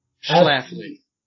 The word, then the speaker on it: Schlafly: If anyone out there knows how to pronounce these right, let me know, because I feel bad about this. – I think that one's Phyllis Schlafly. – Schlafly. Okay. She was a constitutional lawyer and conservative political activist.